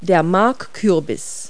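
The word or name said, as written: marrow squash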